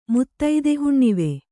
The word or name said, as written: ಮುತ್ತೈದೆಹುಣ್ಣಿವೆ